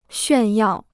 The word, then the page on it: 炫耀: xuàn yào